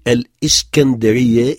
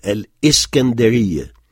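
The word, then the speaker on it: Al-Iskandariya (الإسكندرية): Istanbul, Suez, qui a donné son nom au canal, Al-Iskandariya, donc il faut bien prononcer Al-Iskandariya, il y a une hamzat qata, une hamzat coupure de son après l'article Al-Iskandariya, pour Saïd, Yalta.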